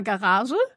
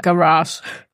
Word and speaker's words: Garage: Also im Deutschen sagen wir immer Garage. – Okay. – Und wenn wir dann aber, sobald wir jetzt ins Englische gehen, sagen wir eigentlich immer Garage.